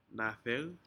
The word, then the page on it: nadec: Aneityum